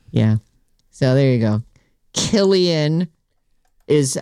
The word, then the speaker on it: Cillian: By the way, we've been saying his name wrong. – Who's? – Cillian Murphy. – Oh, Killian Murphy. Killian Murphy? – It's Killian. Yeah. – Oh, even though it's a C, huh? – I know.